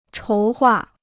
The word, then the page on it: 筹划: chóu huà